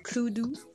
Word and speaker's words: Cluedo: Le Et Cluedo On le prononce très mal, euh, c'est Cloudou. Cloudou.